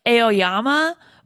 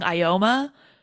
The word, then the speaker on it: Aoyama: So Ioma's having, I believe it's pronounced Ioma. It looks like it would be like Aoyama, but like I was listening to how they were pronouncing it in the movie and it Yeah. – sounded like they were saying Ioma. So I'm going to say it Ioma.